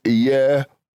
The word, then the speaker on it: Yehovah: Now, some pronounce it another way, but Strong's breaks it down in its syllables as Yehovah.